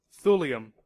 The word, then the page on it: thulium: American English